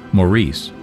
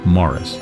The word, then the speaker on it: Maurice: By the way, the title name looks like Maurice, but it's pronounced Morris. Here's music by Richard Robbins, with the orchestra led by Harry Rabinowitz, from the Merchant Ivory production of Morris.